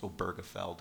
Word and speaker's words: Obergefell: And I'm glad we've sort of come to a consensus that it's pronounced Obergefell, because frankly I had no idea how to pronounce the thing.